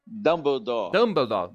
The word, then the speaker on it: Dumbledore: Falei certo? É Dumbledore? – Dumbledore.